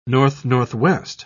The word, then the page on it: north-northwest: British English